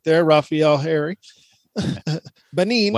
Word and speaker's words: Benin: Benin? B-E-N-I.